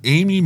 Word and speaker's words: Aimee: Which is spelled, by the way, A-I-M-E-E. – Yeah. It's aim, that gun. – Right. – At me.